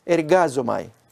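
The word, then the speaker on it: ergazomai (ἐργάζομαι): Pues miremos ahora, el verbo es ergazomai, se pronuncia así, yo trabajo, visto como obra secular en los otros textos del Nuevo Testamento.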